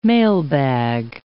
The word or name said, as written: mailbag